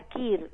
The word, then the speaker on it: queer: ¿Cómo se escribe care? Q-U-E-E-R. Uh-huh. Como, sí, sería KIR, ¿no? – Sí, sí, generalmente se pronuncia así, pero sí.